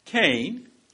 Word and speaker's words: Kaine: Kaine, pronounced the same as Cain, the first murderer in the Bible, who is the vice presidential candidate with Hillary Clinton, is a proud Jesuit-trained Catholic.